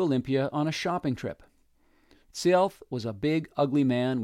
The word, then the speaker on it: Sialth: One day, an Indian named Sialth, pronounced Sialth and sometimes Seattle,